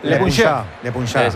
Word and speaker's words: Le Puncheur: No, lo has dicho bien, lo has dicho bien. – No, pero se dirá en Se puede mejorar, Le Puncheur. – Le Puncheur.